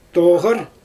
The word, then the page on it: toɣɯr: Xyzyl